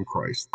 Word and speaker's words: Croce: My other one is Jim Croce.